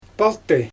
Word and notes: paltyj: Xyzyl

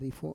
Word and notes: rifo: Roon

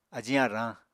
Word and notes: ajiñ arañ: Apatani

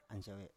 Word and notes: ansiawe: Biak

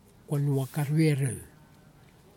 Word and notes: konuwak arwerew: kwamera

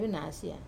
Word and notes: venasia: Wamesa

